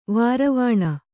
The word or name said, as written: ವಾರವಾಣ